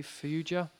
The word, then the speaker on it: Fauja: I'm going to spell it first of all. Okay. F-A-U-J-A. Fauja? – Fauja, yeah.